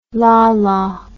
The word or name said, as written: lala